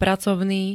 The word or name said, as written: pracovný